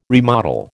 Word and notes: remodel: American English

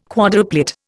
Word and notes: quadruplet: Indian

